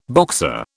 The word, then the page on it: Boxer: British English